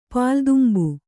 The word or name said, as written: ಪಾಲ್ದುಂಬು